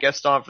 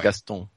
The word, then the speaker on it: Gaston: Yeah, he pronounces it the French way, you know, right. Gaston.